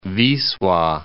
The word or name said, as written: Wisla